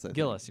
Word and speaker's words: Gillis: Joseph Giles. – Gillis, you're right. Yeah. I said it wrong.